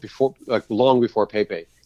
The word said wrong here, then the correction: Pay-Pay: or Pai Pai, if you want to say it right.